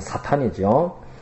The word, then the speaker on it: 사탄: 사단이라는 말은 히브리 말을 그 발음대로 적은 것인데 정확하게 발음을 하면 사탄이죠. 예, 사탄이라는 말은 대적하는 자라는 뜻입니다.